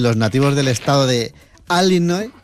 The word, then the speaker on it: Illinois: Y si uno busca ese nombre fonéticamente, es Illinois. Así que Illinois y la S, así que bravo, sin pronunciar la S final, Illinois, una bravo, vez más, bravo, tu apunte de bravo, geografía bravo, que bravo.